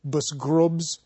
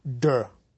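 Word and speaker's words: bsgrubs: One of my favorite is the word B-S-G-R-U-B-S. I suppose if you pronounced it literally, it would be busgrubs. It means something like proven or established in a philosophical sense. It's spelled busgrubs, and it's pronounced de.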